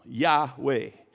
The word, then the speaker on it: Yahweh: They, they removed, the Israelites removed the vowels, but we pronounce them Yahweh, and it's Y A H W E H.